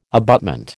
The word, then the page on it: abutment: American English